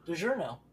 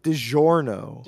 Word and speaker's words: DiGiorno: DiGiorno. – It's DiGiorno. – DiGiorno. – Come on, say it with me. DiGiorno.